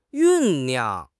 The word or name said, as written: 酝酿